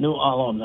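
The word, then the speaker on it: New Ulm: I mean, you go down to New Alum, and New Alum, that's three syllables.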